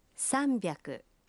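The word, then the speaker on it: «Самбяку» (さんびゃく): Правильно, но с произношением может возникнуть путаница. 300 произносится… «Самбяку».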